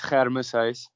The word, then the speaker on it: Germisius: So the actual pronunciation is Germisius. I mean, for English people, it's tough to get that first the part of my surname.